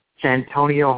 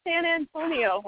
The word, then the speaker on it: Santonio: none other than Santonio Holmes. Note that I actually pronounced his name. – Not San Antonio Holmes.